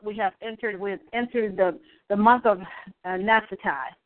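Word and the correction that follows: Naphtali: Nephtali. Yes, excuse my pronunciation.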